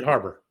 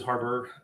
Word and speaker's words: Harbour: David Harbour. – Harbour.